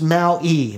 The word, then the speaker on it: Maui: You know, I just want to say Maui is pronounced Maui. Maui. You know, because an I is pronounced an E. And I say Maui a lot, but it's really Maui.